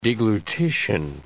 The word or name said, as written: deglutition